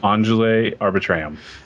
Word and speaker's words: Angeli Arbitrium: Angele Arbitrium.